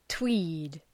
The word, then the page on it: tweed: twi:d